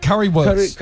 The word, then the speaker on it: Currywurst: Currywurst.